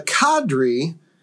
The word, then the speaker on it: cadre: Indeed, those who leave home to become career missionaries need a cadre of consecrated and zealous supporters back home." Now, full disclosure, we did not know how to pronounce cadre. – I'm under the impression it's cadre. – Cadre, like padre.